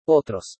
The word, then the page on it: OTROS: spanish